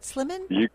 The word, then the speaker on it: Slimmon: Andrew, did I say it right, Slimmon? – That's right.